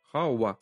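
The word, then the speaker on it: һава: «Хауа». Mm-hmm. А еще в этом слове интересно, что буква «а», она же тоже не произносится чисто, она огубляется, да, вот научно называя, она превращается такая в полу-о.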